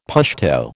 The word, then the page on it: Pashto: American English